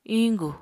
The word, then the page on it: iñgu: apatani